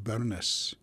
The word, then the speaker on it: Burns: And some of the old-timers in Scotland still pronounce it Burness. My grandmother used to say, we would quote Robert Burness. And that's the old pronunciation of the Burns.